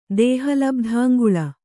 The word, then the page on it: ದೇಹಲಬ್ಧಾಂಗುಳ: Kannada